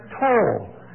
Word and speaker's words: taw: So you had to go back to tow. And that's not a southern pronunciation of T-A-R. It's in the dictionary, T-A-W. And that meant that starting line. So we'd go back to tow and we'd all shoot again to see who went first, and that settled the argument.